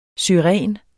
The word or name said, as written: Syringa